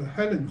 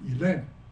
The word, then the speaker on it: Helen: 我说只有法国人的发音会把这个 Helen 发音成 Elaine，好，是真的，这是真的，因为他们 H 是不发音的，好，所以 Elaine 是 Helen，法国人念到 Helen，他就会念 Elaine。